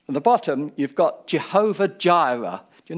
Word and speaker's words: Jireh: But the actual word Jireh is exactly the same as what you've just said. Jireh. Jehovah Jireh. It's the same letters. It's just got different pronunciation.